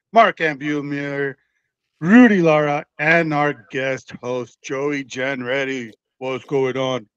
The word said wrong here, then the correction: Genret: Or is it Genret? – Genret, two syllables. – Genret. There we go.